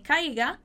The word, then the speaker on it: caiga: Então, nadie quiere que yo me caiga. Nadie quiere que tú te caigas. Muito cuidado com a pronúncia dessa palavra, tá? Caiga. Uma boa ênfase aí nesse I que você tem aí pra falar essa palavra.